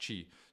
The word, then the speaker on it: qi: It's electrical in nature, which the Chinese call qi, which is pronounced, which is spelled qi in English, right? But it's pronounced qi. So that's why it's called qigong and not qigong.